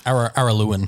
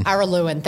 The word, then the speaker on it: Araluan: Well, I can't pronounce that. Arulian goldfields in Victoria. – Araluan. – Araluan. Thank you.